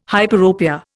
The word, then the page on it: hyperopia: Indian